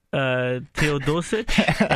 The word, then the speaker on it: Teodosic: Teodosic, I believe.